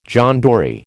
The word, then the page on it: John Dory: American English